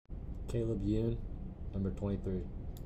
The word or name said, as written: Caleb Yoon